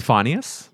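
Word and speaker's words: Finneas: It's pronounced Phineas. – Phineas. – Phineas. – Dubbard. – Phineas. – Phineas Dubbard. – No, it's definitely Phineas because it's Billie Eilish's brother.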